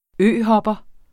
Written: øhopper

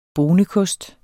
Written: BONEKOSTE

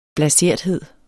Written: blaserthed

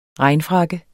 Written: regnfrakke

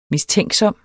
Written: mistænksom

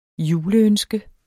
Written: juleønske

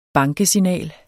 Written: bankesignal

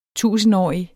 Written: tusindårig

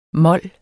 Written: mol

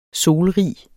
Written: solrig

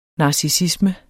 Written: narcissisme

